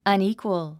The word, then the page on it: unequal: ʌníːkwəl; アニィクオゥ